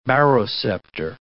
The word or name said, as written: baroceptor